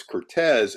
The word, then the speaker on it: Curtiz: So I call him Curtiz, but how he pronounced his name was Cortez.